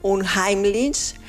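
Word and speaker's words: unheimlich: Se pronuncia, a ver si lo hago bien, un Heimlich, que nombra aquello que es conocido, pero que se vuelve inquietante.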